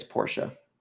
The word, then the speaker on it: Porsche: It's Porsche.